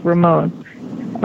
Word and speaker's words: Remon: My last name is spelled R-E, M as in Mary, O, N as in Nancy, pronounced Ramon, R-E-M-O-N.